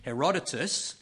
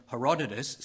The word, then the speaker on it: Herodotus: You know, the ancient Greek historian, and I've got to pronounce this correctly because I got it wrong this morning, Herodotus, the ancient Greek historian Herodotus, said, "In peace, sons bury their fathers.